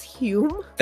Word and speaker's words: Home: Why are we pronouncing this Hume? – That's how it's pronounced. – It says who? – The Scottish. – Oh, the Scottish. Of course they're pronouncing this not home.